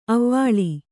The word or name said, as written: ಅವಳೆ